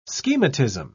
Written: schematism

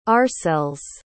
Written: oursels

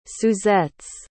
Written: suzettes

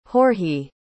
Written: georgiy

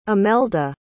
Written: imelda

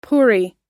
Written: puri

